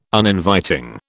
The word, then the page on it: Uninviting: British English